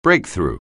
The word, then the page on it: breakthrough: anglais